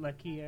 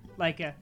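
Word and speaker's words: Laika: I hope Box Trolls wins just so that studio, that Lucky, or how you pronounce it, gets an Oscar. – Leica.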